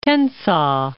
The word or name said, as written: Tensas